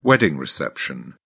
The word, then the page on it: wedding reception: British English